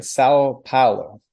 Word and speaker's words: São Paulo: You're in, I'm not going to say it right, and you're going to help me with my pronunciation, but you're in São Paulo.